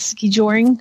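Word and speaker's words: skijoring: She said, I missed the only winter fun event, ski joring practice. Ski joring? Did I say that right? Yeah. ski joring practice.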